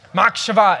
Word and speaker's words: machshava: Machshava, and the B is a V, how it's pronounced.